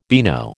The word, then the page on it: beano: American English